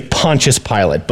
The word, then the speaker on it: Pontius Pilate: I think how you pronounce the name is Pontius Pilate.